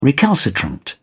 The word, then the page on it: recalcitrant: British English